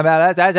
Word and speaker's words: malachite: My malachite so tight. That's how you pronounce it.